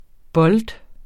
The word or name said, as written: bold